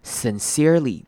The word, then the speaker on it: sincerely: To pluck up the courage to sincerely apologize， sincerely 这个字也是很多人会念错的字，它第一个 I S-I-N 的 I 这边要念成短母音 I 的声音，虽然听起来不会有太大的差别，但是其实还是很容易被发现的，所以很多人会念成 sincerely，但是其实这边的不是念 sin，而是要念成 sin， sincerely sincerely，记得那个 I 是短而急促的 i 的声音 sincerely。